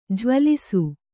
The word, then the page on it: ಜ್ವಲಿಸು: Kannada